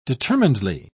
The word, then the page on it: determinedly: British English